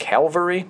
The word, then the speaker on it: Calvary: Is it Calvary? – Calvary, not Cavalry.